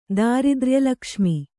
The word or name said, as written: ದಾರಿದ್ರ್ಯಲಕ್ಷ್ಮಿ